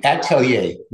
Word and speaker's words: atelier: I can read Richard Lack, but Altier? – It's Atelier is the best idea.